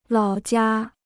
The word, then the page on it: 老家: lǎo jiā